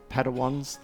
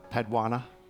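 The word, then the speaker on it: Padawan: And there was it Padawans, the little, now I called Chloe my Padwana.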